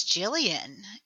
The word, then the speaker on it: Jillian: It's Jillian. It's Jillian. – That's what I thought. – Soft G. – Okay. – I may be like super hard ass sometimes, but it is a soft G.